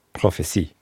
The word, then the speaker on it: Prophètes: The irony here is that you're confused by the fact it's spelled with a T. But when he was, from the time he was, that was pronounced as a C, so it's prophecies.